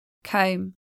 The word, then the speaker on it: comb: Silent B is an exceptional spelling. Bomb, atom bomb. Climb, climb up a cliff. Comb, chrome comb.